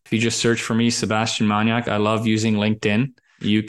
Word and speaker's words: maniak: All right, so now you know how to follow Seb and that uh, maniak. That's spelled uh, like maniac, but with a K. – Exactly. – Yes. M A N I A K dot I O maniak dot I O and uh, and follow Seb and uh, on all the socials.